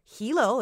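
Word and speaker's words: Ilo: It's spelled H-I-L-O. A place called Hilo in Hawaii sounds familiar, but no, this is actually about a town that's spelled Y-L-O, still pronounced Hilo, and it is a port town in Peru.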